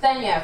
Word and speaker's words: Nthenya: Nthenya from Kenya, and they go, oh my God, it rhymes.